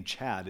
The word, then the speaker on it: Chad: The problem with the name Chad is it rhymes with dad,